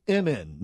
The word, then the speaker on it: Emin: Okay, he also, one of his, that's Goldstone's clients, is a Russian entertainer by the name of Emin, not Eminem. – Not Eminem. – No. – We've had enough of him. – Emin. You spell it E-M-I-N.